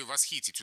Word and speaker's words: восхитить: Сейчас мы говорим, конечно, восхитить. А видите, в слове «восхитить» ударение убежало, и мы застали уже русский язык в том состоянии, когда стало правильным восхитить, восхититься, восхищаться, да.